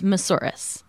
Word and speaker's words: Moussouris: No, not really. It's Moussouris. – Moussouris. Okay. – It's like a dinosaur. Katie Moussouris Rex.